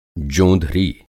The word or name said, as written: जोंधरी